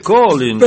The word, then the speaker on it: Collins: Hoy tengo para vosotras una canción de mi admirado Phil Collins. – Por favor, don Romualdo, se dice Collins. – Pero se escribe Collins, lo malo es que acabara en ons.